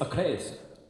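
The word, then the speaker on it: ACRAZE: Craze, Do It To It, which, by the way, who thinks it's pronounced A Craze? – I think it's A Craze. – It's A Craze. – It's A Craze. His manager, Alex, said A Craze.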